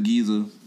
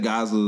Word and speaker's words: Giza: And then I mispronounced the pyramids of Giza. I said Gaza or some shit.